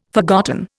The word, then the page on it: forgotten: Indian